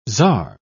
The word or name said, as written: czar